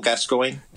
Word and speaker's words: Gascoigne: like Lineker and how do you pronounce that? The Alan Shearer. – Shearer too, but the one that considered him like a second father. – Yeah, Paul Gascoigne. – Gascoigne, thank you.